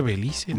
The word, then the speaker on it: Belice: En español mexicano se llama Belice, se le dice Belice, ¿no?